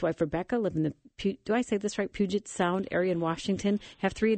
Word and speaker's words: Puget Sound: Oh, you said it perfectly. Puget Sound, indeed.